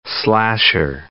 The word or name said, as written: slasher